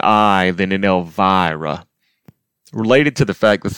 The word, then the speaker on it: Elvira: It's Elvira. Not Elvira, for example. Elvira.